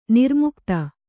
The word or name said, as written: ನಿರ್ಮುಕುತ